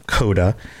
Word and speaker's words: C0DA: All right, so Coda, and it's spelled C0DA, Coda.